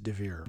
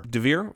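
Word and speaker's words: Deavere: I'm going to pronounce all the letters. Diavere. It could be Devere. – Okay. – Diavere, I'm not sure, but Anna Diavere Smith. – I think it's just Devere. – Devere, fair enough.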